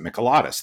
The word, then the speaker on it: Mikalatos: So for instance, my last name, which I told you, Drew, to pronounce it Mikalatos. That's how I pronounce it. That's how my whole family pronounces it. That is not the Greek way to pronounce it.